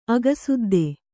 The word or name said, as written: ಅಗಸುದ್ದಿ